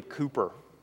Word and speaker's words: Cowper: one of my favorite hymn writers is a guy named William Cowper. It's spelled Cowper if you want to look him up.